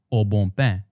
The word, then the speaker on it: Au Bon Pain: But if you were to pronounce these words the French way, which has strong nasal sounds, then the bon becomes bon and pain becomes bain. Notice that the nasal vowel sounds are so strong that you don't even hear the ends anymore. So altogether, the name of the sandwich chain is Au Bon Pain.